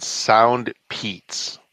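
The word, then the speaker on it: SoundPEATS: They're called Sound Peats, like the name Pete. – But I think it's spelled P-E-E-T.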